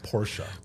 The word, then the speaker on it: Porsche: By the way, it's pronounced Porsche.